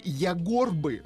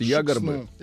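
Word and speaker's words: Ягорба: района, расположен при впадении реки Ягорбы в реку Шексну. – А вам сейчас поправят, скажут Ягорбы.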